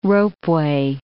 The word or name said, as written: ropeway